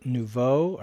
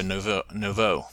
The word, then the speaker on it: Nouveau: It came up with a hardware conflict on boot between my video card and Nevu driver. Is that a driver? – I'm not sure what that is. I think you pronounce it Nouveau.